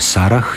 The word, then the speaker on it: Sarah: U nominativu i hrvatsko ime Sara i strano ime koje se piše Sarah izgovaraju se jednako.